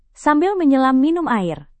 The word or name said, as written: sambil menyelam minum air